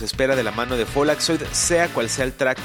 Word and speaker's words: Follakzoid: Ellos se llaman Folaxoid, se escribe F-O-L-L-A-K-Z-O-I-D. Folaxoid, que es eh, como decir encendedor en alemán, pero pronunciado pues así, Folaxoid.